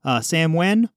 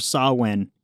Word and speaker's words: Samhain: They called it Sam... Sam Wen or Sa Wen. Either of those are apparently the appropriate pronunciation. – Yeah, something about it not originally being English.